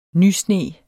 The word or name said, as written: nysne